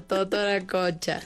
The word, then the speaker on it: Totoracocha: Viernes 15 de mayo, Feria de Víveres de Totorococha, de 9 a 1 de la tarde. Viernes 15 de mayo, Feria de Víveres de, a ver si lo pronuncié bien. – Totoracocha.